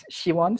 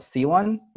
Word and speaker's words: Siwan: So in Korean, it's Siwan. So it's like a she sound. – She, right. Siwan. – Yeah, you say it correctly. I call myself C1, which is wrong pronunciation, but I just got used to it.